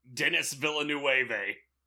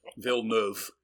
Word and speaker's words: Villeneuve: And you taught me how to pronounce his name. I never knew. I thought I would have said Dennis. Denis Villeneuve. – Villeneuve.